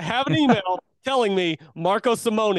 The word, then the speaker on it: Simone: It's actually pronounced Marco Simone, which I think is very weird. I've been pronouncing it Simone for a long time now, and now I'm being told via this pronunciation email for the Ryder Cup, Marco Simone Golf Club, which sounds off.